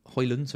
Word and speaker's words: Højlund: FPL Prasim says if Rasmus Højlund is 7 Højlund, sorry, you didn't pronounce the D, is 7.5, that's a real bargain straight in my squad.